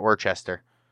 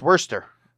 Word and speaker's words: Worcester: But it's called Worcester, by the way, guys. Not Worcester. – Worcester. – It's Worcester.